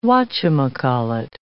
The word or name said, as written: whatchamacallit